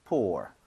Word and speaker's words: pour: 있는데 발음 자체는 차이가 없습니다. 그래서 pour, pour. 똑같이 해주시면 돼요.